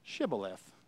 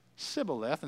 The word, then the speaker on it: Shibboleth: Shibboleth. Okay? If he said Sibboleth instead of Shibboleth, all right, because they could not pronounce it correctly, they seized him and executed him at the fords of the Jordan River.